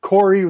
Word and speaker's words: Vedvik: Let's quickly touch on Vedvik, the Corey, as we learned how you pronounce his name, because I pronounced it Care last week, Corey.